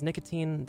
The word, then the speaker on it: Nikatine: My name, of course, is Nicotine. That's N-I-K-A-T-I-N-E.